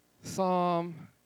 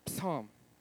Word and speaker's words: Psalm: Psalm, it's P-S. Spell it P-S. Psalm. Everybody there?